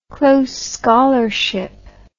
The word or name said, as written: close scholarship